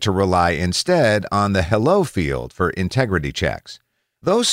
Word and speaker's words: HELO: Take the fact that email headers actually have two from fields, H-E-L-O, pronounced hello, and mail from.